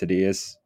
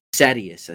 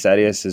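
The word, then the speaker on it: Sadeas: So anyways, chapter 6, Kaladin arrives at Prince Sidious. Sidious? Is that how you pronounce it? – God dang it. Sadius, I think. – Sadius? – I think it's Sadius.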